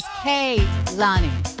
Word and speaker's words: Kehlani: And just to clarify for everybody, it is Kehlani. Like Alani, you know, the energy drink, but with a K at the beginning.